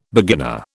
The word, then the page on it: beginner: British English